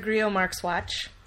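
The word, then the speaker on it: Grillo-Marxuach: I found Les McLean, who did the Middleman comic, which was written by Javier Grillo Marxwatch. Yes, that's how it's said. I looked it up.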